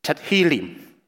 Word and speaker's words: Tehillim: 在希伯来文里面，它的读音是叫做 Tehillim。